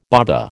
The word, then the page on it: baba: American English